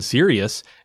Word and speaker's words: Sirius: Yeah, Sirius.